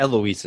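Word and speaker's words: Heloise: Eloise is right. – That's correct, right? – The H is silent.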